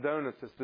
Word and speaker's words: Donatists: I know you've all heard Donatists for your whole lives. I believe it's pronounced Donatists.